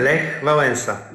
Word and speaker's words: Lech Wałęsa: Lech Wałęsa.